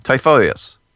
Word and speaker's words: Typhoeus: Typhoeus. How do I pronounce it? – That was actually correct.